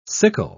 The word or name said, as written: sickle